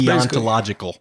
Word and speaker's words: deontological: That line of thinking is called deontology. I'm not sure how you say it, but it's deontological. – Deontological. There it is.